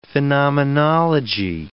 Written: phenomenology

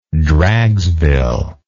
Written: dragsville

